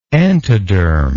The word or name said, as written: entodermic